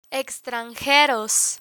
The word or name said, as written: extranjeros